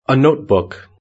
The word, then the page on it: a notebook: British English